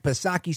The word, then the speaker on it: Psaki: When we come back, we're going to talk about what's happening in the White House, the transfer of power from Jen Psaki Silent P to Karine Jean-Pierre, the first LGBTQ White House press secretary.